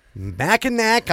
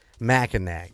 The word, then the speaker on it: Mackinac: I just found your podcast and was excited to find one on Mackinac Island, but cringed every time you pronounced it Mackinac.